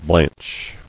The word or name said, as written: Blanched